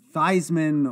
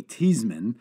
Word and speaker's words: Theismann: And my grandfather, until the day he died, called him Joey Theismann. Of course, Theismann changed the pronunciation of his name to rhyme with Heisman when he was at Notre Dame.